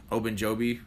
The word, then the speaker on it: Ogunjobi: Um, you get him with uh, Ogunjobi. Did I say right? Yeah, I said it right.